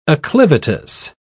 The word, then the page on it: acclivitous: British English